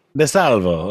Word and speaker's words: DeSalvo: Hopefully I said that correctly, Mark DeSalvo. You say it with the proper accent, right? – But if you want to throw the Italian on there, DeSalvo. – DeSalvo, of course.